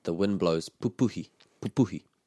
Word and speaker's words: pupuhi: Just with that word, titiro, whenever we have this double up in the two first syllables, papaki, titiro, pupuhi. So you got the two first syllables in a word of three syllables. The stress is on the middle one.